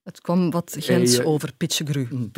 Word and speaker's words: Pichegru: Ja, Pichegru. Ik wist niet hoe ik het moest uitspreken. – Ja, Pichegru, ja.